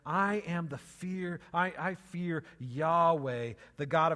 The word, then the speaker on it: Yahweh: It's Yahweh, right? Sometimes pronounced Jehovah. It's four Hebrew letters, and so we have to fill in our own vowels, and so you pronounce it different ways.